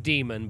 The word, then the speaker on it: Daemon: But it is a lot like Daniel Suarez book, Damon. – I think it's pronounced Demon, but it's spelled Damon. – D-A-E-M-O-N.